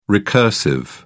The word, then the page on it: recursive: British English